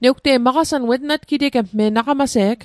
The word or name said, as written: natgiteget